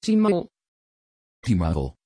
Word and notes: timael: Dutch

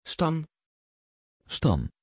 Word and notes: Stan: Dutch

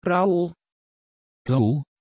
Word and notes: raúl: Dutch

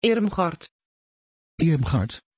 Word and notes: irmgard: Dutch

